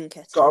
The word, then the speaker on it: Nketiah: So what happened is Eddie Nketiah, I think that's how you pronounce his name, got Nketiah.